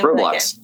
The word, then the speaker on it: Roblox: What did hit, and this is what we talked about the Thursday group, what did hit is Roblox? How do you say the name Roblox. – Of that game? Roblox. Okay.